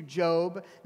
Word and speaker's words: Job: If you're new to the Bible, it's spelled like Job, J-O-B, but it's pronounced Job.